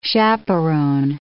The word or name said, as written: chaperon